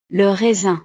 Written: le raisin

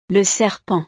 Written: le serpent

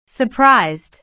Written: surprised

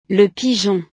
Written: le pigeon

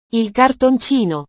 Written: il cartoncino

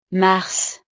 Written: mars

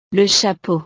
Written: le chapeau

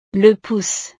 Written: le pouce

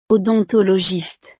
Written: odontologiste

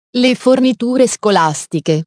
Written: le forniture scolastiche

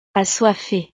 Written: assoiffe

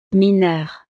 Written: mineur

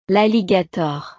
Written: l'alligator

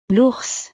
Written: l'ours

